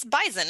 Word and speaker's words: Bison: It's Bison. It's even though it's spelled B-I-S-O-N, it's more of a Z sound than an S sound.